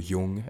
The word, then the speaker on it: Jung: like Carl Jung would call it, or Jung, as some people pronounce it, which is basically integrating your conscious with your unconscious.